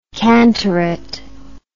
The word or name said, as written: cantorate